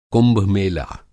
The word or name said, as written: कुंभ-मेला